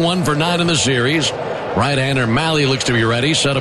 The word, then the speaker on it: Mahle: Tyler Mahle, last name spelled M-A-H-L-E, and pronounced Mahle, rhymes with rally. Heard a few different pronunciations, and Mahle is the one that he goes with.